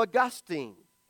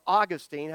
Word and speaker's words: Augustine: He went back to Augustine, or Augustine, however you want to say it. I've heard it pronounced both ways. That's why I say it both ways.